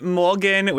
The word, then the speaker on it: morgxn: We're chatting with Morgan with a silent X.